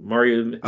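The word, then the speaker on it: Mario Hezonja: is that how you say his name? Mario or Zonia. Hizonia, that's his name. Mario Hizonia.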